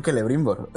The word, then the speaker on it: Celebrimbor: Dime Lo que me ha sorprendido es que has pronunciado bien Celebrimbor, no, no otra cosa. – No la ha convertido en aguda, no la ha convertido en aguda. Se ha quedado con eso de antes.